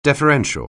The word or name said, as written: deferential